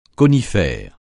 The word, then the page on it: conifère: francais